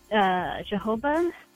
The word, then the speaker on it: jojoba: So we've always been, make sure you put vitamin E and jojoba. Or I don't know how you say it. – Jojoba, yeah.